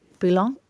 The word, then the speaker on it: bouillon: And so I went into the vegetable shop, and it's this little kind of, it's like a little stock pot, but it's, how would you pronounce this? – Bouillon? – This is French. – Yeah. – Bouillon.